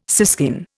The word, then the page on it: siskin: Indian